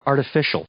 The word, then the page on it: artificial: adjective